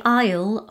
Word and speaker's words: Isle: Also, notice the pronunciation, because quite a few of our students pronounce the S in Isle and Island, like Isle and Iceland. It's a silent consonant.